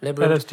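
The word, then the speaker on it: Labrinth: Diplo und Labyrinth oder Labyrinth, wie wird das ausgesprochen? Labyrinth. – Labyrinth.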